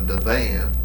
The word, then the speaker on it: divan: Well, they didn't call it a sofa, they called it a divan. And then there were some that pronounced it just like it's spelled. They called it a divan.